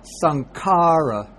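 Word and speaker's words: śaṅkara: The N with a dot on top would be pronounced like in the English word song, NG, or ring, NG. So like we say sangha or sankara. You see, it's there's no other way you could pronounce it. It always has to come out like sing or song or ring.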